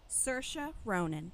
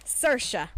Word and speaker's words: Saoirse: Say Jo's name again. – Saoirse Ronan. – Okay. I just, that is how you say it. – Don't know how to say it. – Saoirse.